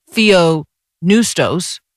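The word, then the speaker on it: theopneustos: So that's one of those silent P words. And it's the pneuma, the breath. So theo, neustos, literally God breathed.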